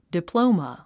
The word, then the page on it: diploma: diplóumə; ディプロゥマ